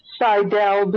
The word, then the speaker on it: Saidel: And let me just say it's Saidel before you say it wrong again.